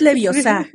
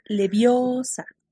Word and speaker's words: Leviosa: no, es, no es leviosa, es leviosa.